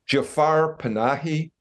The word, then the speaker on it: Jafar Panahi: Jafar Panahi.